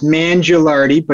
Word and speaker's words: Mangialardi: It's Mangialardi, but you get Mangialardi, you get A+ for effort.